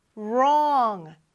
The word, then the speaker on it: wrong: 자, 물론 WRONG이지만 W는 소리가 이렇게 나지 않고 R 소리가 납니다.